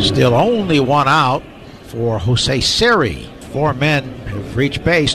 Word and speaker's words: Siri: Jose Siri. – And now you said it right. And so you did it. Now, when he does it, he puts the emphasis different.